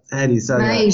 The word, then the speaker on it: Meij: He just pipped the Domino's CEO, Don Meij, I think his name is. I don't even know how you say that. M-E-I-J. How do you say that? – Meij, is it Meij? – Yeah.